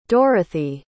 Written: dorothee

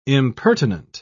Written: impertinent

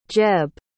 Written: jeb

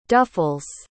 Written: duffels

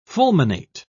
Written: fulminate

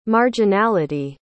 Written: marginality

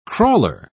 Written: crawler

